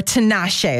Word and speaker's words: Tinashe: Well, Calvin Harris was reported to be dating Tinashe, or Tinashe is actually how you pronounce it.